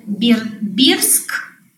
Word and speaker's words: Бердск: Я в Берске живу, Берск, вообще он пишется Бердск, но все говорят Берск, скит Бера, то есть силовое место, три реки как бы вокруг, тайга.